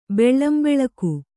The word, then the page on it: ಬೆಳ್ಳಂಬೆಳಗು: kannada; beḷḷambeḷagu